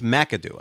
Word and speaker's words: McAdoo: It's McAdoo. I don't know why you're mispronouncing every name.